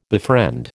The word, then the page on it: befriend: American English